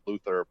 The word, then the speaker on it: Luthor: I think one of the better things to happen towards the end of the show was their introduction of John Cryer as Luthor. And well, some pronounce it Luther, but I still remember the original 1960s Superman cartoon that always pronounced it that way, and that's gotten into my head, so you know.